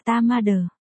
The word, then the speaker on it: TMD: TMD, phát âm là ta ma đờ.